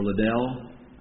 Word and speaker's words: Liddell: I've wondered if there might be a similar sort of pressure that Eric Little or Liddell, Little I believe is how it's usually pronounced, I wonder if it's the same pressure he felt when he declined to run on a Sunday in the 1924 Olympics.